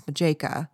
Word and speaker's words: Matjeka: A man was standing outside her small apartment and introduced himself as Detective Thomas Majeka. Definitely a Forensic Files name because of the spelling, M-A-T-J-E-K-A, but the T is silent, I don't know.